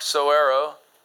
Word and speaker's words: saguaro: Cutting down a cactus like Arizona's iconic saguaro, that's how you pronounce it, by the way, is a crime punishable with up to 25 years in prison and a hefty fine.